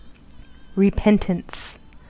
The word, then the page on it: repentance: American English